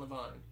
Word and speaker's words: Levine: Pretty sure it's Levine. – Is it Levine? – I've never heard anybody say Ken Levine.